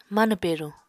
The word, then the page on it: manw peruñ: apatani